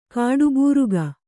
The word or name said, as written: ಕಾಡುಬೂರುಗ